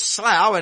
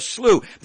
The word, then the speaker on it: slough: He fell into what here in England, I think you pronounce as slough, and in the United States they call it a slough, the slough of despair or the slough of despondency.